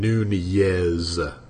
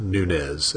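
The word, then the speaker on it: Núñez: So it's the Spanish pronunciation, Nunez, not Nunez, as they were pronouncing it on the game the other day.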